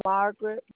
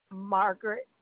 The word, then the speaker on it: Margaret: It's Margaret Boyd, B-O-Y-D. – So that's your first name as well. – Margaret, M-A-R-G-A-R-E-T.